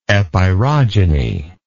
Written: epirogenetic

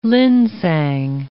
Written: linsang

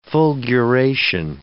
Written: fulguration

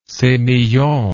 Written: semillon

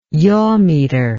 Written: yawmeter

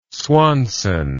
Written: swanson

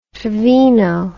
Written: trevino

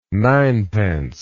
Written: ninepence

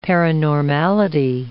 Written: paranormality

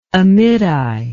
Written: Amittai

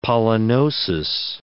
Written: pollenosis

